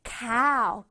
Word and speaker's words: cow: 아우 소리를 내줘야 돼. cow, 길게. cow, cow.